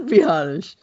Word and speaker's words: Biadasz: Now I say I say Biadasz. No, I want to actually say Biadasz. – You said it right, though. You said Biadasz. – I know I said Biadasz the first time, but it still screwed me up, man.